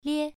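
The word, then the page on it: lie: tone 1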